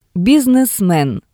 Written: bisnesmen

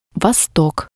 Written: vostok

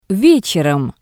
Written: Vetserom

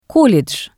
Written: colledj

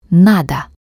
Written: nado

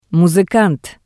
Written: musikant

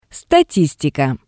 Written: statistika

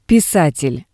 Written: pisatel

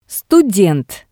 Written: student

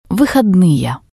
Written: vihodnie

